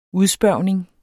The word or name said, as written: udspørgning